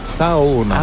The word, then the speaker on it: Saona: En fait, on prononce chaque lettre, donc c'est Saona.